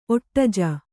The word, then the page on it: ಒಟ್ಟಜೆ: kannada